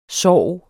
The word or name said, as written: sorg